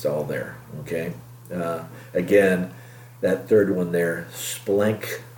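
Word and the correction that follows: Splanchna (σπλάγχνα): No. Is that how you say it in the Greek? Splanchna.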